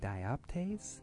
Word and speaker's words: dioptase: It is made up of five different copper-based elements, including malachite, chrysocolla, shatakite, smoky quartz, and dioptase? – Yes. – Did I say that correctly? – You did, yeah.